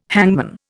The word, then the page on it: hangman: Indian